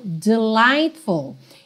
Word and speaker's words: delightful: Então, começando pelo final, delightful. Esse GH aí do meio, você pode esquecer, tá? Não tem pronúncia.